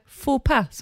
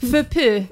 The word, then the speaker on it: faux pas: Socialt snedsteg, eller faux pas som det heter på franska. – Fupi kanske. – Nej, så uttalas det verkligen inte.